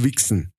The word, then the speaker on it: Vixen: Da gab, es gab sogar mal, ähm, es gab ein, äh, mal eine äh, weibliche Heavy Metal Band, die hieß Wixen. Also V-I-X-E-N.